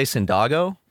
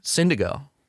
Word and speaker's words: Syndigo: Syndigo. It rhymes with indigo.